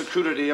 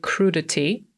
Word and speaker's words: crudity: Tutaj mamy słowo crudity. Tak wymawia to doktorek. Po brytyjsku powiedzielibyśmy raczej crudity, I ono to jest rzeczownik, który pochodzi od przymiotnika crude, pisanego crude.